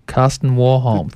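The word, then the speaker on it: Karsten Warholm: His name's Carsten Warholm. – Carsten Warholm. Thank you. – Good pronunciation.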